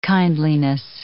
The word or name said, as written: kindliness